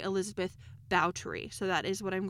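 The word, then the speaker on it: Báthory: Now, the English pronunciation of her name is Elizabeth Bathory, but her Hungarian name is Elsbeth Bautry.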